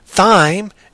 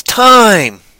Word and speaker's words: thyme: It's not thyme. It's time. I was actually pronouncing that one always correctly because it's like I always pronounce the time. – I always called it thyme. – Well, for the first couple of years in my head, I called it thyme. Then I heard my English teacher say it was time. It's like, oh, okay, so that H is silent.